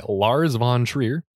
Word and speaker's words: Lars von Trier: This is directed by Lars von Trier. Is that at all the correct way to say that? – Yes, that is the correct way to say it.